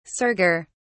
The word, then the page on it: serger: British English